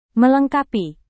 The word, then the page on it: melengkapi: Indonesian